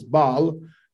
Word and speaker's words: Baal: The proper pronunciation is Baal. That's the Hebrew pronunciation.